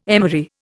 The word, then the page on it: emery: Indian